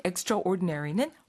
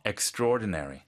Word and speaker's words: extraordinary: 사실은 extraordinary는 훨씬 그 위를 말하는 건데 특출한 이런 뜻이 되잖아요. – 근데 그 단어 자체가 네. 스펠링은 extra하고 ordinary인데 네네. – 발음이 좀 특이해요. – Extraordinary.